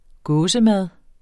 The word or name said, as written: gåsemad